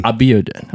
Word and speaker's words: Abiodun: Abiodun.